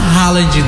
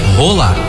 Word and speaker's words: Haaland: E você sabe qual é o pronunciamento dele mesmo lá no país dele? Como é que fala? Não fala Haaland, não. – Não? – Não. – Como é? – Rola.